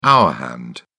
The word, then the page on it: hour hand: British English